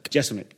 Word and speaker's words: Jeselnik: Is it Jeselnik or Jeselnik? – Jeselnik.